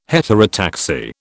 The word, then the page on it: heterotaxy: British English